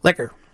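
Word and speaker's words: pot liquor: You also like the brown sugar-glazed salmon, the roasted chicken over collard greens with pot liqueur. – Liquor. if we're going to pronounce it.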